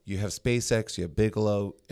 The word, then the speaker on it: Bigelow: I'm going to really butcher this, but Bigelow? – Yeah, Bigelow Aeronautics.